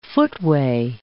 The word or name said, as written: footway